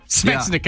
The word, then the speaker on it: Svechnikov: The Wings' top prospect, Evgeny Slichenov, is, I probably just butchered that name. You want to take a stab at that? Take a stab at that name. – Svechnikov? – Svechnikov. Yeah. Okay, you cheated.